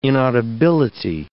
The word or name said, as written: inaudibility